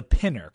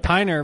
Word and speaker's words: Pinner: It's the Pinner, correct? P-I-N-N-E-R. – Yes, you are 100% right. The Piner would be like that beer that you had on that had the pine taste to it.